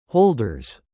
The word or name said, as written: holders